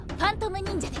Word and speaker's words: 幻影忍者: 読み方はファントム忍者で。